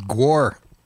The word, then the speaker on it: Gwar: I say Gwar, but it is Gwar, isn't it? – Yeah. – Yeah, because it's war. – War. – Yeah. – G-war. – It's a G-war.